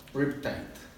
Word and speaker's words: Riptide: Riptide.